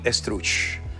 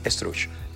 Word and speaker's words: Estruch: Tony Struch, ¿ok? E-S-T-R-U-C-H, Tony Struch.